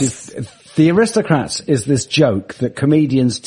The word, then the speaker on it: The Aristocrats: There was a screening of this film called The Aristocrats.